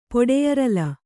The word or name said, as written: ಪೊಡೆಯರಲ